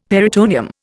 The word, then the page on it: peritoneum: Indian